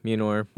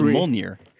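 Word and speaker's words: Mjölnir: on Mjolnir. – Mm-hmm. – So did I say that right? – No. Mjolnir. – So you did not. – Mjolnir. – Nope. – Still wrong.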